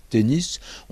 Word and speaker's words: tennis: Alors, revenons à ce mot tennis. On sent bien qu'il a un parfum anglais. En français, d'ordinaire, on n'a pas vraiment de mots dont l'S final se prononce.